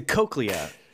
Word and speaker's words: cochlea: Well, it's the cochlea, which is a part of the ear, but it's spelled C-O-C-H-L-E-A.